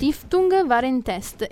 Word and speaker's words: Stiftung Warentest: Si legge così, eh? – Ah, okay. – Stiftung Warentest e okay.